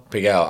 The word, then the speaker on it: Pigalle: Is that how you pronounce it? Pigalle? Pigalle? – Pigalle. I would say Pigalle.